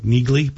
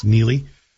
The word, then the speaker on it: Neagley: Francis Neely, and by the way, if you're looking for it on Amazon, it's spelled N-E-A-G-L-E-Y. Sort of looks like Neegley, but it's pronounced Neely.